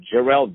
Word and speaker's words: Jerrelle: It's J-E-R-R-E-L-L-E. Jerrelle. And he has released this phenomenal track with a beautiful music video, which I invite everyone to watch on his Vivo channel. That is Jerrelle Vivo.